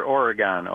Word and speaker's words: Oregon: Well, we say it here, Oregon, Ohio. – Oregon, okay. As opposed to Oregon, yeah. Like the state. Oregon, correct.